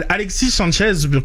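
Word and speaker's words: Sánchez: C'est pas un ami de hispanophone, mais Alexis Sanchez, comme sans, sans la prononciation du S, c'est généralement ce que j'ai entendu.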